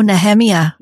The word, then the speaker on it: Nehemiah: I do a ton of different teachings on YouTube and I'm going to mispronounce it because I know he pronounces it differently. Nehemiah, is it Gordon? – Oh, Nehemiah. – Nehemiah. – Nehemiah Gordon. Yes.